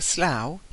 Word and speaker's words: Slough: After falling into a slough, he coughed and hiccuped.